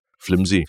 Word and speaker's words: flimsy: So only, I think, my 30s or something that I realized that I've been saying it wrong my whole life. I've been writing it and saying it wrong my whole life. It's flimsy.